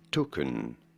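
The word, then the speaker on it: toucan: In British English, this is normally said as token. You do want to stress on the first syllable.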